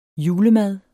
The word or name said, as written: julemad